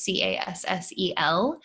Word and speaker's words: Cassel: And if you're listening to this, you can see the way my name is spelled is C A S S E L.